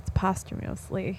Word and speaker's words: posthumously: But he mispronounced posthumously. – You're like, say that again. – I'm like, oh, it's posthumously.